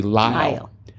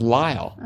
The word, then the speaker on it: lisle: According to my partner, who is a textile designer, the correct pronunciation for L-I-S-L-E rhymes with tile. So it should be Lyle.